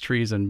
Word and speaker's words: Treeson: I just want to make sure I spell that out because it's, it's pronounced treason, but it's T R E E S O N.